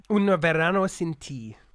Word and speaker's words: Un Verano Sin Ti: Un Verano Sin Ti.